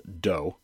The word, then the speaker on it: DoH: Comcast's version of DNS over HTTPS, or DOH, pronounced DOH, will be turned on by default for Firefox users on Comcast broadband network.